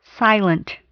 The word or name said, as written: silent